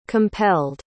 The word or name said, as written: compelled